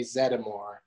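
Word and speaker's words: Zeddemore: Because if you're a huge Ghostbuster fan, Zedmore sounds weird, because you hear it a bunch of times in the movie and it's definitely Zedmore.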